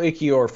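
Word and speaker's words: Ekiyor: Emil Ikior from Alabama, and I'm only about 50% that I'm pronouncing that correctly. – Sounded great to me.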